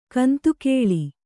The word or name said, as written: ಗೊಂತುಗೊಳ್